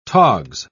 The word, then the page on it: togs: British English